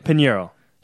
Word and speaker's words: Pineiro: Pineiro.